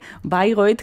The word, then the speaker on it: Bayreuth: Bueno, no me sorprende Bayreuth, que lo pronunciamos a la alemana, pero que se escribe, para los oyentes que no lo sepan, Bayreuth, está en Alemania, pero sí, hay quien se escuchan pronunciaciones extrañas de esta localidad.